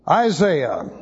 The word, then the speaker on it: Isaiah: Turn to the book of Isaiah. We're interested in chapter 3, but let's go to verse 1 to see how it all gets started. Isaiah, or as the British say, Isaiah.